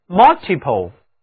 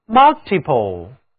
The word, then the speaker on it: multiple: 最后我们看一下词根 ple 加上表示多的前缀 multi， M-U-L-T-I，这就是单词 multiple, multiple。这里字母 u 读作三角的元音啊。